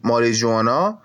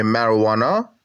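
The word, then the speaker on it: marijuana: ماریجوانا که تلفظ صحیحش میشه مروانا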